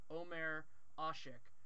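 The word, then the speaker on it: Ömer Aşık: Also, I looked up after we recorded it, and the NBA pronunciation guide has it as Omer Asik, and so that's going to be hopefully how I pronounce it from here on out.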